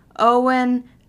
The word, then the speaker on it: Eoin: For example, Owen, as in Owen Colfer. Owen is spelled E-O-I-N, which to the non-Irish language speaker or non-Gaelic speaker, it's very unintuitive.